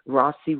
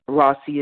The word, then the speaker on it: Rawsi: You can follow me on social media, Rossi Williams. Just put in my name, Twitter, Instagram, Facebook. Rossi is spelled R-A-W-S-I.